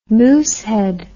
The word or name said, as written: moosehead